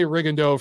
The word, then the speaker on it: Rigondeaux: And by the way, I've been saying Rigondeaux because that's how I was told to pronounce it all during this broadcast. The entire broadcast team kept saying Rigondeaux. So I will say Rigondeaux from here on henceforth, because that's the way they were saying it. So I've heard this name pronounced 8 billion different ways. We're going to go with Rigondeaux.